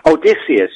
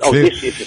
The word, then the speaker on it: Odysseus: Odysseus, yeah. – Odysseus. – It's from Greek mythology. I'm not a Greek mythological expert, but Odysseus is how I've always pronounced it.